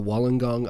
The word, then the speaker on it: Wollongong: World's, so today kind of wrapped up the road cycling world championships out in Wollongong, Australia. And yes, that is how you pronounce it.